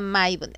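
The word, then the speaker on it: Mabon: se escribe Mabón sí y se pronuncia Maibun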